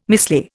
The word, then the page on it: mislay: Indian